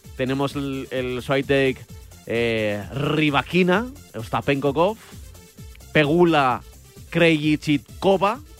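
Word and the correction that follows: Krejčíková: ¿lo he dicho bien? No, no. Krejcikova. – bueno, Krejcikova.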